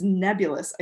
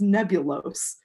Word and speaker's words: nebulous: to say it's unclear, he's trying to say it's nebulous. I think he says it's nebulose.